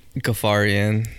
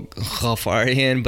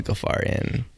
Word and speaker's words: Ghafarian: It's Persian, and you know, I I don't say it the way that my family says it, but I feel like if I did start saying it, it's a more guttural Ghafarian. But if I said it the way that you're supposed to say it, nobody else would pronounce it right. So it's better, it's easier, more palatable as a Ghafarian.